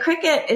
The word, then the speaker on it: Cricut: But cricket is spelled C-R-I-C-U-T. And for years, I was pronouncing that Cricut. And when I was talking to her, I'm like, oh my gosh, I love Cricut. And she's like, oh yeah, no, it's actually pronounced cricket.